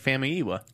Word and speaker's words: Famuyiwa: And coming back to back with the Rick Famuyiwa news. – Yeah. – And I hope I'm pronouncing that right, Rick Famuyiwa. – I believe so. That's how I say it.